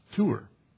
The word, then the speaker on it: tuwr: Now, the word search there, in Hebrew, it's the word, and it's spelled T-U-W-R, but it's pronounced tour.